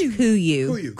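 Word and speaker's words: Kuiu: It's pronounced Kuyu.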